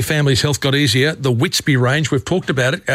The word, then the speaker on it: WitsBB: Now, Whitsby, it's WITSBB. That's how it's spelt on the bottle and on the products, but you say Whitsby.